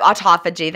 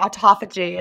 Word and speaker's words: autophagy: I definitely wasn't getting all the benefits that I'm now getting clean fasting with autophagy. – Yeah, autophagy. That's, you can hear other people say it differently. I think people in like Australia pronounce it differently. But yeah, I think autophagy is.